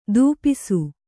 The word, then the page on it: ಧೂಪಿಸು: Kannada